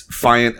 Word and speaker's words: Fiant: So, right, so where did that my name is Jonathan Fiant, but it's Fiant, F-I-A-N-T. Gets mispronounced or misspelled all the time, like faint, flant, whatever.